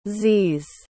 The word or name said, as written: z's